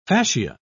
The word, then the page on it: facia: British English